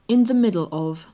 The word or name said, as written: in the middle of